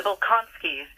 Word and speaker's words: Bolkonskys: There we would discuss the Bezukhovs and the Bolonkovsky. Pronunciation here. I'm going to mess up all these names. The Bolkonskys,